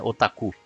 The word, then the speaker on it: otaku: Dentro da estranheza que é o idioma japonês, otaku é uma palavra proparoxítona, mas não importa a pronúncia que você queira dar a ela. Continua feia de qualquer forma. Eu já, já de novo, né? Eu tô. Esse é o paroxítono, então, então é otaku.